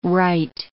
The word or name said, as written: rite